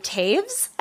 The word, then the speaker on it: Toews: Apparently, her name is pronounced Miriam Taves, I think, but it's spelled T O E W S.